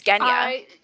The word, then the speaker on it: Genya: And I will maintain how much I fucking hate that that's the pronunciation of her name. It is Genya. – It's not Jif. It's Gif. It's Genya. – It's Genya.